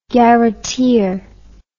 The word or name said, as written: garreteer